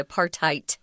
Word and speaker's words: apartheid: And the D isn't pronounced, you're actually saying a T, apartheid.